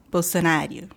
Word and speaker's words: Bolsonaro: Bolsonaro.